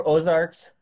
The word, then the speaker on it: Ozark: And I should say it correctly. It's Ozark. – Yeah. Just like uh, just like it's not uh, Alaskan Airlines, it's Alaska Airlines, things like that. But it's just Ozark.